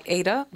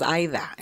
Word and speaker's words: Aida: A lot of people call me Ada because that's the English pronunciation for the name, which is normal. But my grandmother's name is Ida. And she would always tell me, that's not your name, it's my name. So you make sure people say it correctly.